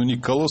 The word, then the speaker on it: Никола: Для меня тоже было удивительно, но Никола стоит с ударением по-итальянски на последнем слоге, так что это, наверное, правильное наименование.